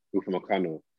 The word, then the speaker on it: Upamecano: Upamecano.